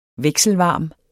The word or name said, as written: vekselvarm